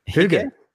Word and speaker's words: hygge: First, there was, and can you pronounce it for me? Hygge? – Hygge.